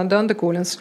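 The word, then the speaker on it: Dando-Collins: Стивен Данде Коллинз, правильно я произношу? Да, Данде Коллинз, написал эту самую книгу.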